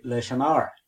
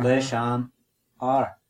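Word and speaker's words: leis an fhear: F-H in Irish is silent. This is another little grammar rule. So we say leishanar.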